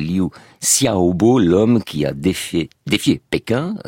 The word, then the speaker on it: Liu Xiaobo: Nous venons de raconter l'histoire de Tiananmen, et nous avons cité pour terminer ce récit des vers de Liu Xiaobo.